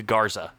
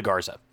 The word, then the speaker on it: Garza: It's not Luca Gonzalez, Luca Garza for Iowa. That's my mistake. Luca Garza. Just needed to correct myself.